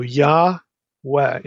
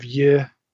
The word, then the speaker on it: Yahweh: and it's always with an A vowel, Yah, always. So Yahweh instead of Yehweh or Yeh.